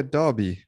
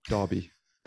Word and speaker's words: Derby: Yeah, Derby. I know it's a difficult one for you Australians, but yeah, Derby. That's the way you pronounce it.